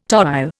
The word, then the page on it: toile: Indian